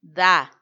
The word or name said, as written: da